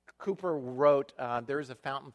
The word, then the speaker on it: Cowper: One of the great hymn writers of the faith, his name William Cowper, it looks like Cowper, but it's pronounced Cooper.